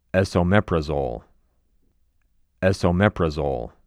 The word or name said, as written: Esomeprazole